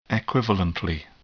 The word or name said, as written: equivalently